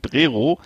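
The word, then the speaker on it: Přerov: Regenbogen Camping Prero, geschrieben äh, für Westdeutsche Prerov mit OW am Ende wird aber O ausgesprochen, so wie die ganzen, Orte.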